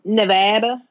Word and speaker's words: Nevada: Since y'all are both from, one's from California, the other's from Nevada. – Yep. You get points for saying it correctly.